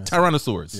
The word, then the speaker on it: Tyrannosaurus: Tyrannosaurus.